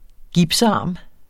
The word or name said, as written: gipsarm